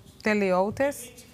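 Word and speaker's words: teleoutes (τελειωτής): Y lo busqué en el griego. Se pronuncia teleoutes.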